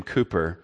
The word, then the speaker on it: Cowper: There's a really good song by a guy named William Cooper, God Moves in a Mysterious Way. We've sung it here. We sing it every once in a while. It's spelled C-O-W-P-E-R.